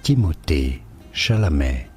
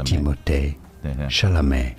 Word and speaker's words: Timothée Chalamet: In French, it is said as Timothée Chalamet. Timothée Chalamet.